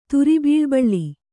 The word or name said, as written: ತುರಿಬೀಳುಬಳ್ಳಿ